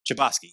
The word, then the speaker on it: Chbosky: Stephen Chbosky? – Chbosky.